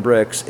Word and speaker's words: Brix: We still have the corporate name Home Brew Shop LTD, but Broken Bricks is the winery name, and it's spelled B-R-I-X.